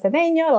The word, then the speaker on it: Cedeño: My name is Ramona Cedeno, like Josh said, and he pronounces my name so well.